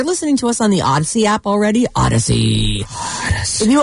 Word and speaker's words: Audacy: Odyssey. – Yes, Odyssey. If you don't have the app, it's free. It's spelled A-U-D-A-C-Y, like think audio. Audacious. Odyssey.